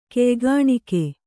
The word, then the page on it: ಕೆಯ್ಗಾಣ್ಕೆ: Kannada